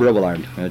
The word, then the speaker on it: Robillard: Bob Robillard, just the way it's spelled, it sounds. R-O-B-I-L-L-A-R-D.